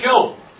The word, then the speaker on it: Job: Now, the book of Job. Turn to the book of Job. That's spelled Job. Okay? J-O-B.